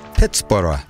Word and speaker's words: Pittsburgh: Forbes, who hailed from Scotland, dubbed the settlement between the rivers Pittsburgh, pronounced like Edinburgh.